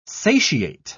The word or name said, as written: satiate